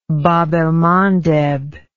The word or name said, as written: bab el mandeb